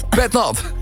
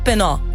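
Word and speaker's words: pét-nat: Pet nat. – Gewoon lekker plat. – Ik heb gisteren voor het eerst in mijn leven pet nat gedronken. – Maar spreek je het als pet nat of penna? – Pet nat.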